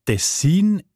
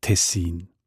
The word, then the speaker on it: Tessin: Die Variante Tessin ist häufiger. Die ist im Schweizerdeutschen Wörterbuch Idiotikon belegt im Kanton Bern, in der ganzen nördlichen Schweiz, aber auch im Innerschweizer Kanton Schweiz und Siselen und Sonderschächen im Kanton Uri. Auch im Hochdeutschen sieht man Tessin. Die Variante mit der Betonung auf den ersten Silbe und mit kurzem I, also Tessin, ist seltener.